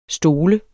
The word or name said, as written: stol